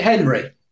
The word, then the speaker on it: Henri: Well, it's spelled Henri, but I think he pronounces it Henry. – Does he? Right. Well, yeah, it's spelled with an I.